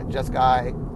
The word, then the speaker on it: Jeskai: And we named them, let's see, the white one was Abzan, the blue one, centered one, obviously, was Jeskai, is that right, Jeskai? Yeah, Jeskai.